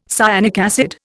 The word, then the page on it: cyanic acid: Indian